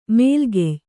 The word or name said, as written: ಮೇಲುಗೆಯ್